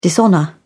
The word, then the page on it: dishonour: British English